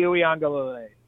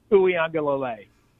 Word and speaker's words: Uiagalelei: DJ Uiagalelei. – Now spell it. – All right. U. I. A. N. G. A. L. E-I-L-A, Uiagalelei.